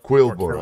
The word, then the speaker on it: Quillbor: Is it Quillbor or Killbor? – Quill, like a... – Quillbor, okay.